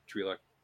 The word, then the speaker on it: Twi'lek: Speaking of that, too, like we got the Ryloth mentioned in this episode, too, for the Twi'lek planet. Or Twi'lek. Star Wars has two pronunciations for a lot of things.